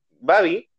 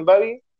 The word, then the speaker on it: Babi: Y había uno que era em, protagonizado por la serie Alegría, y se llamaba eh, Babi, entre paréntesis, se, lee, se pronuncia como se lee, que era Baby, pero decían Babi, se pronuncia como se lee.